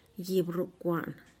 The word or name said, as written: gibrukuaan